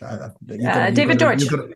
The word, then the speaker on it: Deutsch: Deutsch or Deutsch? – David Deutsch. – David Deutsch. Yeah, there you go.